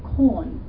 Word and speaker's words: Quorn: So I think one of the more popular ones at the moment now is something that's called corn, but it's spelled Q-U-O-R-N.